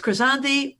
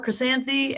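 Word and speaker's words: Krysanthi: So it's Chrysanthi or Chrysanthi, and it's spelled with a K. So it's Chrysanthi.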